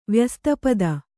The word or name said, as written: ವ್ಯಸ್ತಪದ